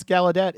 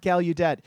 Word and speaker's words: Gallaudet: Yeah, that's how you pronounce Gallaudet. It's not Gallaudet.